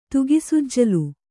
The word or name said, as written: ತುಗಿಸುಜ್ಜಲು